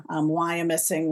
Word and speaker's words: Wyomissing: Wyomissing was a community that grew up around. – I know I wasn't pronouncing it right, and you would get me.